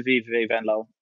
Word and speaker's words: Venlo: Now, I had spoken about when I went to VVV Venlo, and well pronounced, by the way.